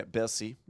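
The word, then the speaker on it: Bercy: It's like Mercy, but with a B. – Yeah, Bessie.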